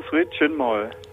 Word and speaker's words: Sri Chinmoy: So, jetzt muss mir nochmal erzählen, wie spricht man diesen Namen überhaupt nochmal aus? – Du hast richtig ausgesprochen, Sri Chinmoy.